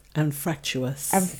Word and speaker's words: anfractuous: Anfractus. Oh, I said that wrong. Anfractuous.